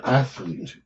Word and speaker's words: athlete: It's athlete. – Athlete. – It's athlete. – Two syllables. Athlete.